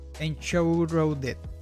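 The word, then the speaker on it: Enshrouded: Se trata de En Show Road Dead se pronuncia así.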